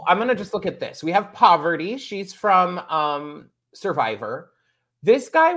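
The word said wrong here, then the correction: Parvati: Not Poverty, but Parvity. – Parvity. Oh, okay. You have to enunciate.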